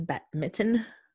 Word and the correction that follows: badminton: B-A-D-M-I-N-T-O-N. – Badminton? – Yes.